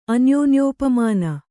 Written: ಅನ್ಯೋನ್ಯೋಪಮಾನ

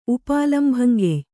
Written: ಉಪಾಲಂಭಂಗೆಯ್

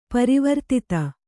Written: ಪರಿವರ್ಧಿತ

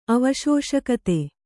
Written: ಅವಶೋಷಕತೆ

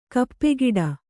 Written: ಕಾಪುಗಾಡು

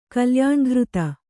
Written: ಕಲ್ಯಾಣಘೃತ

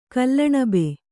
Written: ಕಲ್ಲಣಬೆ

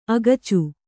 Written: ಅಕಚ

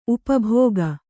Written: ಉಪಭೋಗಿ

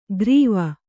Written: ಗ್ರೀವ

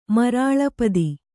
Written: ಮರಾಲಪದಿ

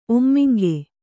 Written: ಒಮ್ಮಿಂಗೆ

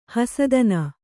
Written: ಹಸುತನ

